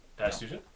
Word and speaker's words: Batistuta: Marco van Basten, Oliver Bierhoff, Gabriel Batistuta, is that how you pronounce it? – Yeah. – Batistuta, yeah.